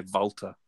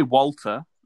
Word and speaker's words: Walter: But we say Walter. You say Volta. Yeah, I prefer Volta because it's an A and not an O.